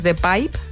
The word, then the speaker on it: Pijp: A mí un barrio que me gusta mucho también es The Pipe, que se escribe P-I-J-P.